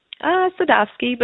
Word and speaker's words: Sadovsky: Did I pronounce that right, Sarah? – Sadovsky, but yeah, pretty close.